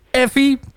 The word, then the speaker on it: FV: Effie, dat is zoals ik het correct heb uitgesproken.